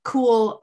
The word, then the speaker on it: Kuel: Now, Jack is the owner of a company called Cool Life, which is actually kind of cool, the name, because it's spelled K-U-E-L.